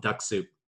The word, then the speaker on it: DuxSoup: James, I had an issue that talked about an app called Duck Soup. And what Duck Soup is, it's spelled DuxSoup.